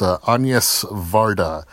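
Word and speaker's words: Agnès Varda: I believe it's pronounced Agnes Varda. It's spelled like Agnes, and I thought it was Agnes, but then I heard somebody say it.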